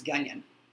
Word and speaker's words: Gunyon: No, they mispronounced Gunyon. – Okay. – Which, all right. – G-U-N-Y-O-N. It's spelled like Funyon with a G. And I spelled it, like when I wrote it out, I put G-U-N and then hyphen and then Gunyon.